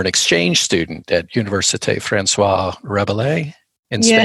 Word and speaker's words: François Rabelais: So it's François Rabelais.